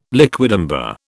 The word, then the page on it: liquidambar: British English